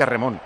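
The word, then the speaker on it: remón (レモン): Mira, en japonés, limón se pronuncia remón, entonces tendríamos portero.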